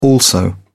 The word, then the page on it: also: British English